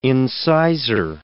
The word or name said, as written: incisor